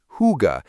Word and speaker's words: Hygge: This is the world of Hygge, pronounced Hygge, Denmark's gift to our overworked, overstimulated society.